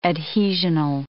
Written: adhesional

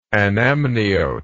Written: anamniotic

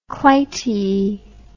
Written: clytie

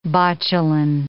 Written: botulin

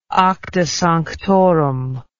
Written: acta sanctorum

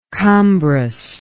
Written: cumbrous

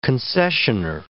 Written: concessioner

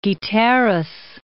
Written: Guiteras

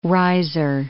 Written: riser

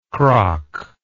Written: krak

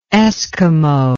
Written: Esquimauan

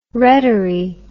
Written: rettery

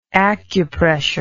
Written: acupressurist

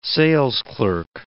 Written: salesclerk

